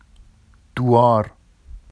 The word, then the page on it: duɑr: Vafsi